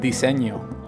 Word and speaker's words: diseño: Diseño.